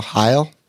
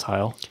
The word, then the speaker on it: Heil: H-E-I-L. – Heil? – I think it's Heil. – Heil. H-E-I-L is the brand name.